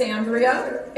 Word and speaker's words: Andrea: My name is Andrea, a N D R E A, last name H E D S T R O M.